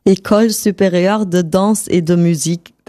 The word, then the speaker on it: École supérieure de danse et de musique: Wie wird das richtig ausgesprochen? – École supérieure de danse et de musique.